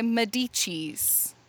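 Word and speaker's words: Médicis: Okay, so not long after that time, medieval Europe, fast forward a few hundred years to Catherine de Medicis. Is that how you would say her name? – Sure. – Sure.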